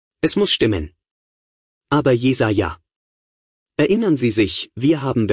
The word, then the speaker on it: Jesaja: Der Prophet Jesaja, oder du sagst Jesaja. Ich muss mich immer erklären. Ich bin in Schottland zur Schule gegangen und habe mein Postgraduiertenstudium gemacht, und so wurde Jesaja ausgesprochen. Und es ist mir einfach im Gedächtnis geblieben, also sage ich es immer noch so.